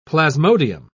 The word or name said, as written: plasmodium